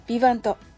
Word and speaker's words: VIVANT: このビバント、英語のように発音されていますが、実はフランス語です。